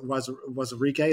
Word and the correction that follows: Anudike: Is that right? Wazirike. Fair enough. Yeah. – Anudike. – Whatever. Yeah, Anudike.